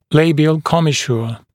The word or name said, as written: labial commissure